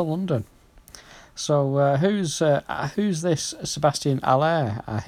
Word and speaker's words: Haller: If you say it correctly, because he's a Frenchman. Haller.